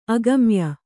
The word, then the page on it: ಅಗಮ್ಯ: agamya